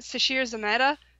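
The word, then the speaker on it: Sasheer Zamata: And also David Arquette is in it, and Sasheer Zameda.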